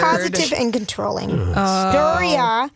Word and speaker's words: Doria: how do you say her name? Doria? Yeah, I mm-hmm. think that's Doria accurate.